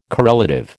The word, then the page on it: correlative: American English